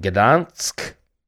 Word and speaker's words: Gdansk: She's from Gdansk.